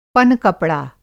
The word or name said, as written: पनकपड़ा